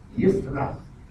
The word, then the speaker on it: Yisra: Samuel, Ezekiel, Ezekiel, Yisra, you pronounce Israel as Yisra, Elijah, two names, El and Jehovah, Elisha.